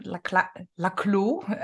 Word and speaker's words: Laclau: And to put it in Laclau's or Laclau, as it is pronounced in Argentinian, I was told once, to put it in their terms, it would be the presence of the other prevents me from being fully myself.